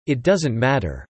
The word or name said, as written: It doesn't matter